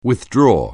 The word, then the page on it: withdraw: anglais